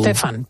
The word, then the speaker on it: Stefan: Stefan, pero bueno, es el Stefan.